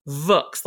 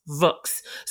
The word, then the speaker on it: Vooks: It's called Vooks, like rhymes with books, Vooks.